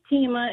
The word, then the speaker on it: Teima: Hi, my name is Tima. It's spelled T-E-I-M-A. And I absolutely love my name. My mom, we all have unique names. But all through school, my teachers always mispronounced my name. Taima, Tiima, Thelma.